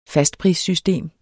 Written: fastprissystem

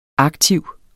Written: aktiv

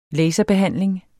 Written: laserbehandling

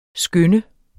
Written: skynde

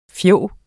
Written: fjog

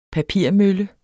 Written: papirmølle

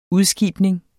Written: udskibning

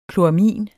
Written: kloramin

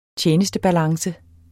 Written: tjenestebalance

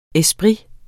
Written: ESPRITERNE

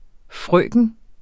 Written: frøken

